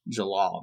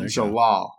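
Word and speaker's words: Jellal: Just in abilities alone, he's pretty BA, but he's I got Jalil, I want to say that's how you say it from Fairy Tale. – Jalal. – There you go. So yeah, Jalal.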